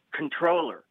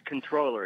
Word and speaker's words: comptroller: It's spelled C-O-M-P-T-R-O-L-L-E-R and pronounced controller. – Oh, it's not comptroller? – No, it's controller. It's one of those silent P.